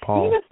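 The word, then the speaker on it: Poul: It's P-O-U-L, and I think it's pronounced Paul.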